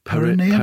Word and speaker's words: perineum: Someone suggested Efan Okoko's perineum. – Okay. – Is that how you pronounce it? Perineum? – Perineum.